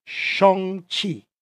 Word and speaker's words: Shang-Chi: I've been saying Shang-Chi my whole life. His name, the correct pronunciation is Shang-Chi.